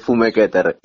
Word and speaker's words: Fumeketter: Es fumequeter, y está bien, como lo habías presentado. Sí, es, de origen alemán, eh, mi abuelo era alemán,